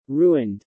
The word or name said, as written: ruined